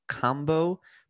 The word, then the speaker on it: kambo: a kambo practitioner.